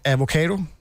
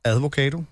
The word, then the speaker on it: avocado: Avocado, kan man udtale advocado.